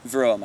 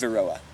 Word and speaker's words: Varroa: Varroa mites. – Varroa mites. – Varroa.